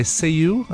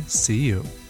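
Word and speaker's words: Céu: And we're going to kick things off tonight with a cover by Seiyu. I believe that's how it's pronounced. C-E-U.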